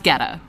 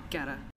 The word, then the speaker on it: Goetta: And it's pronounced Ghetta. – Ghetta. – G-O-E-T-T-A.